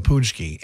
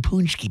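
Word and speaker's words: pączki: I love all varieties of donuts, but there is a definitive difference between that and a poonchki. – P-A-C-Z-K-I, in case you're wondering. – The spelling throws people off, too. They're like, they're saying poonchki, but why is there a C and a Z in it?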